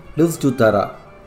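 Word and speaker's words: Livsnjutare: The word of the week is a Swedish word which is pronounced Livstutara.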